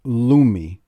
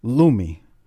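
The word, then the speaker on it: Lumé: There's a product called Lumi, L U M E, and it's got the little fancy squiggle above the E, I believe. You know, so it's pronounced as a or whatever. – Yeah, I think it's a Lumi.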